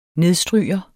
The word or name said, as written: nedstryger